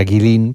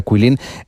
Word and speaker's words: Guilin: Bueno, esta ciudad de Kuilin, por si alguien la busca en internet, se escribe Kuilin, como si fuera Guilin, pero se pronuncia Kuilin.